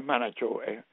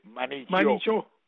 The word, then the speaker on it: manager: No es manachó, manichó.